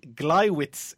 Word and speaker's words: Gleiwitz: So, ladies, your first 2 pieces of information are the Gliwitz incident, that's spelled Gleiwitz, and Fall Weiss, Fallweiss.